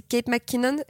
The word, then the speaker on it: Kate McKinnon: c'est Kate McKinnon, ça se prononce comme ça.